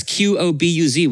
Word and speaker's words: Qobuz: It's Qobuz.